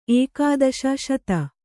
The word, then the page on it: ಏಕಾದಶ ಶತ: Kannada